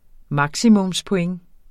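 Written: maksimumspoint

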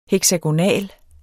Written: heksagonal

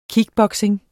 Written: kickboxing

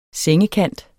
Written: sengekant

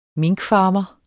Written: minkfarmer

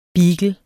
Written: beagle

